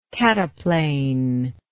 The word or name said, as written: cataplane